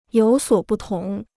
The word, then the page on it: 有所不同: yǒu suǒ bù tóng